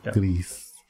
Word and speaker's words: Gris: And Grease is Gris. It's it's Spanish. – Grease.